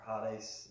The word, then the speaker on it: Hades: The Greek term is Hades here, or Hades is how you pronounce it in Greek, but translated as hell in the King James Version.